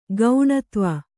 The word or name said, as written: ಕಣ್ದೀವು